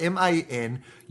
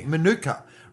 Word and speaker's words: Manuka: It's spelled Manuka. Manuka. Right, that used to be called Manuka, and then the Queen came to Canberra and pronounced it in one of her speeches, Manuka, and they changed it was pronounced.